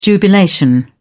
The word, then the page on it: jubilation: British English